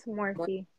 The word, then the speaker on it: Morphe: Morphe? Is it how you say it? – Morphe. Mm-hmm. It's Morphe.